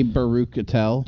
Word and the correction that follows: Baruchel: Baruchel, that's what it is. – Whatever. I can never pronounce his last name. – Jay Baruchel, yeah.